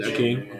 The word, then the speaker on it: Achane: I could take Devin Achane. Is that how you say it? – Achane, okay.